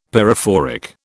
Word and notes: pyrophoric: British English

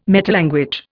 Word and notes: metalanguage: Indian

